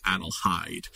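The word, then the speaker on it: Adelheid: Mrs. Bruce, when she tries to settle her down in this episode, pronounces the hard H and says Adelhide.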